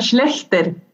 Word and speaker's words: Schlechter: Así que mi nombre es Camila Baraya y hoy tengo como invitada en nuestro episodio 11 a Irene Schletzer, ¿Schlechter? ¿Cómo se pronuncia bien tu apellido? Se pronuncia Schlechter.